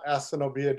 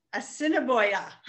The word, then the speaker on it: Assiniboia: So just so everybody knows, you have to earmuff this when your child hears this, but it's not Assiniboia Downs, it's just Ass Downs. – Assiniboia.